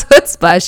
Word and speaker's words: chutzpah: It is chutzpah. So even though it's a ch in the front of it, it's pronounced chutzpah.